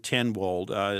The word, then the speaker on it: Tenwolde: Kind of do some research on there, and I would go ahead and look up Anton Tenwald. His last name is spelled Tenwolde. It's Anton, Anton. And just Google that and myths of moisture and ventilation.